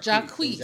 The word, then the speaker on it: Jacquees: How do you pronounce his name? It's Jacquees. – Jacquees. – Jacquees. – Jacquees. – It's Jacquees. – Is the Q silent? – Jace? – No, it's Jacquees.